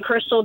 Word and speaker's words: Chrystal: Just remember that my first name is spelled C H R Y S T A L. Hammer, just like the tool, and Crystal, just like the stripper.